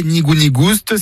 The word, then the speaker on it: nigou-nigouste: Nigou-nigouste, avec prononciation du S originel que l'on retrouve dans le provençal goustus, goûteux, et qui a été remplacé depuis par l'accent circonflexe dans notre orthographe moderne.